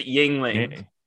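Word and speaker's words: Yuengling: You're adding an extra syllable. Just Yingling.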